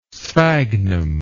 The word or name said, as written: sphagnum